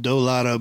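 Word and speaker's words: Doughlato: It's called Dolato, but it's spelled like D-O-U-G. – Dough, like I got the dough.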